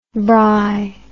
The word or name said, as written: braai